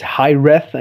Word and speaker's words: hiraeth: yeah, it's uh, yeah actually uh, it's pronounced with a th at the end. It's high reth,